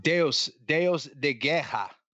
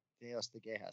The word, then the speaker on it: Deus de Guerra: Deus de Guerra, God of War. Is that right? – Deus de Guerra. – Deus de Guerra. See? – Spell it. – Yeah. Right D-E-U-S, then D-E, and then G-U-E-R-R-A.